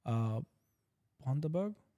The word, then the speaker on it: Bundaberg: How do you pronounce that brand again? – Bundaberg.